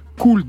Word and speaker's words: Koul: Aujourd'hui donc un nouvel album pour Soulance, Beautiful, 15 titres, dont celui-ci, Cool dans mon dos, j'ai bien prononcé Oui, mhm. tout à fait. – Cool, c'est K-O-U-L.